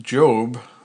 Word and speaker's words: GOB: His name is an acronym for George Oscar Bluth II, pronounced Job, like the biblical figure Job, spelled J-O-B.